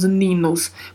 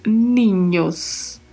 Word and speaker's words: niños: E a palavra é escrita com um N com um til em cima e este N tem som de NH, o som do NH do português. Então não pronunciamos ninos, pronunciamos ninhos. Ninhos.